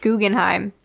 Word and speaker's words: Guggenheim: Guggenheim.